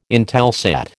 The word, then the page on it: Intelsat: American English